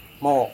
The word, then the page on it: mo: serewen